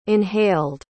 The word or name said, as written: inhaled